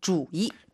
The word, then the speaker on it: 主意: 生, 主意.